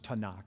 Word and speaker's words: Tanakh: In Hebrew, that's the Torah, the Nevi'im, and the Ketuvim, from which you get TNK, which is sometimes still to this day pronounced Tanakh.